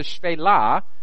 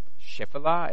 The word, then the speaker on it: Shephelah: And then those of the Shvelah, the only reason I know that it's pronounced Shvelah, by the way, and not Shephelah, as it says, is because I was in Israel, and every time I said Shephelah, people were like, no. Shvelah, you know, so now I know. That's how you pronounce it.